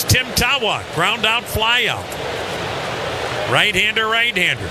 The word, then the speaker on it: Tawa: You spell his last name, T-A, WA. It is pronounced Tawa.